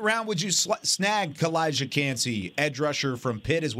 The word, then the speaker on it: Calijah Kancey: Calijah Kancey.